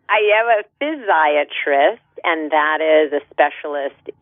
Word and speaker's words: physiatrist: Psych- yes, physiatrist.